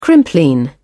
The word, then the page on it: crimplene: British English